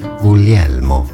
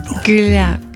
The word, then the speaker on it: Guglielmo: Guglielmo. – Guglielmo. – Guglielmo.